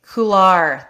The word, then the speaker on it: couloir: Did I say that right? Couloir? – Or couloir. – Couloir. There you go.